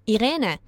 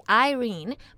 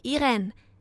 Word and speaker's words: Irene: Hello, I'm Irene. In English, it's supposed to be Irene, but since I'm half Japanese and half German, it's pronounced differently. It's such a wonderful experience for me to travel around Japan and to get to know the beauty of Japanese culture. Thank you. – Bonjour, je m'appelle Irene.